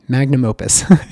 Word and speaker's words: magnum opus: But we're putting this all together in a grand package, my magnus opus, magnum opus. We say it wrong, magnum opus.